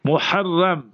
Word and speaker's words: Muharram: So we have Muharram with a ha, Muharram.